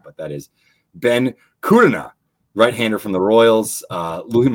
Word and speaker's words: Kudrna: Ben Kudrna, I believe that's right, or Kudrna.